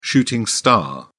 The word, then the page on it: shooting star: British English